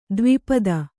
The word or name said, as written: ದ್ವಿಪದ